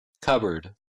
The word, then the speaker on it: cupboard: Буква P не читается в следующих словах. – Cupboard. Psychology. Pneumonia. Raspberry.